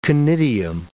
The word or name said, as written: conidium